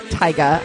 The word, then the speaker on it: Tyga: For context of how popular this was, there are remixes of Clothes Off from Ghostface Killah and Tyga.